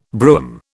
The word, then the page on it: brougham: British English